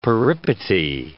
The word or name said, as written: peripety